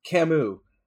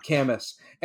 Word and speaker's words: Camus: And then at the end, the professor was like, yeah, but his name is pronounced Camus, not Camus.